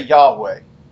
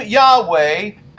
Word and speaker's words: Yahweh: Now, we normally, we put some vowels in there and we say Yahweh.